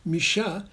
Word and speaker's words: Mucha: And in fact, the French, who pronounce Mucha Micha, called it Le Style Micha, and only eventually it became Art Nouveau.